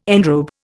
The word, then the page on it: enrobe: Indian